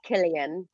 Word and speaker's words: Cillian: I just recently found out that it's pronounced Killian.